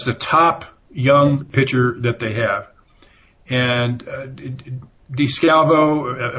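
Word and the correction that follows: DeSclafani: I'm sorry, I'm mispronouncing his name now, Anthony DeScalfani.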